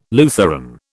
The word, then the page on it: Lutheran: British English